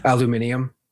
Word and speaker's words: aluminium: Well, I mean, there's some big ones that people know, like aluminum. – That's a big one. – Aluminium.